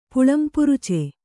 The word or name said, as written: ಪುಲ್ಲಂಪುರುಚಿ